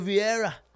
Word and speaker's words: Vieira: Fabio Vieira. You got to say it right, bro. Vieira.